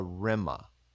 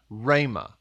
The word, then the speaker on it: rhema: rhema. So first off, it's actually pronounced rhema. – Rhema. – Uh-huh. – Okay. R-E-M-A, R-H-E-M-A. – Right, rhema.